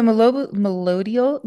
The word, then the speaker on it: Melodiol: Did I say it right? – Melodial. – What is it? So Melodial Global Health is a international plant-based company out of Australia.